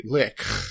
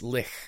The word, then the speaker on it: lich: Yeah, because he's seen that she's gone completely batshit insane because she was turned into a lich. – Yep, we discovered that's the proper pronunciation of the word lich is lich. – Yeah, you have to add the extra phlegm, though.